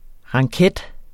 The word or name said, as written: ranket